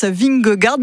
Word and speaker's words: Vingegaard: Vous m'avez dit le premier au hasard, Jonas Vingegaard. Bah, c'était bon, c'est lui qui est actuellement maillot jaune.